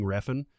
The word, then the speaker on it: Refn: And that is the correct way to say his name, really? – By the way. – See, now, I would have never guessed Refn was right. – Yeah. – That's how an American would pronounce that name. It seems like that would be like Ren or Rayun or something like that. The F has got to be silent, right? – Yeah, it's got to be a J.